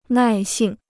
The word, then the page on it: 耐性: nài xìng